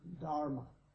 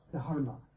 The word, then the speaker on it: Dharma: By the way, it's D-H, so that means it's a D and you blow out a little bit of air. It's not Dharma, okay? It's Dharma. And he's not Buddha. He's Buddha. Two D's and an H. That's how it's pronounced in proper Pali and Sanskrit.